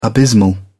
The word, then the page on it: abysmal: British English